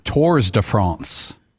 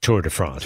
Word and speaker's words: Tours de France: But you don't pronounce the S. – The S in Tours. – Yeah, so it's Tour de France.